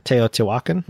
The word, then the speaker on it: Teotihuacan: I always get the pronunciation messed up on this one. I think that one of the biggest Rondel games is Teotihuacan. Is that Teotihuacan? – City of Gods? – Yeah. – Teotihuacan. Mm-hmm.